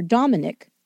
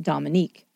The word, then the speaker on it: Dominique: For one thing, Dominique Dunn would say that the judge continually mispronounced his daughter's name, calling her Dominic instead of Dominique.